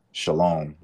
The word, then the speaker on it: Shiloam: to Shiloam, which is spelled S H I L O A M.